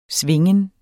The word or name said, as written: svingen